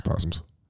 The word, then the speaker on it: opossums: Yeah, it says opossums. I know it's possums. Why is the O silent? – I don't know. – Don't put that there. – Dumb animals. – A silent O is not a thing.